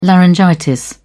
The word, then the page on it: laryngitis: British English